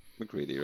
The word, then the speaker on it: Macready: How do you say MacReady? – MacReady, right? Good.